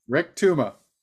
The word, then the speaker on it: Rektuma: This movie, Rektuma. Is that how you say it? – Rektuma. – Rektuma. Oh, like tuma.